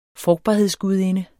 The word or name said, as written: frugtbarhedsgudinde